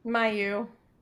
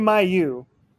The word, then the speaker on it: Mailloux: But it was the draft pick made – by the Montreal Canadiens at number 31 overall from the London Knights, Logan Mailloux. – Thank you. Logan Mailloux.